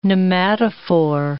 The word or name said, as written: pneumatophore